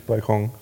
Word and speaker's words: Balkon: Balkon, Balkon, ne? Balkon. Ja. Es gibt auch Leute, die einfach so die Silbenbetonung tauschen, die dann Balkon sagen.